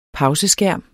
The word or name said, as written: pauseskærm